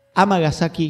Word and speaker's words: Amagasaki: A ver, Amagasaki, Hyogo, Japón. Ahí está, mejor pronunciado.